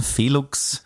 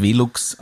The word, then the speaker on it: Velux: Danke an Felux. Heißt Velux, aber schreibt man mit V.